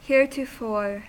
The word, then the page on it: heretofore: American English